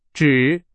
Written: 只

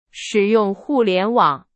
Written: 互联网

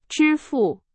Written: 支付

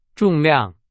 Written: 重量

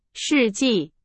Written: 世纪

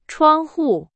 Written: 窗户

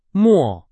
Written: 末

